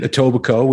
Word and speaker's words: Etobicoke: That's where he grew up, in Etobicoke, which is, of course, just outside of Toronto. – Right? So he was born in Toronto, grew up in Etobicoke, proud graduate of Richview High School, which is right around the corner from where I lived for many, many years.